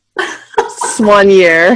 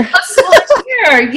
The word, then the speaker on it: soigneur: Soigneur. – Soigneur, yes, for professional cycling team.